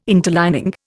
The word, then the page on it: interlining: Indian